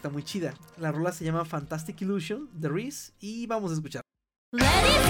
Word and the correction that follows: Iris: Ah, se pronuncia eh, Iris, perdón. No, sé.